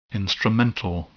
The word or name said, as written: instrumental